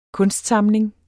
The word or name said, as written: KUNSTSAMLINGEN